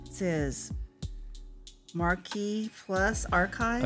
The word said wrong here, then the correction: Macross Plus: Okay, it's pronounced Macross Plus.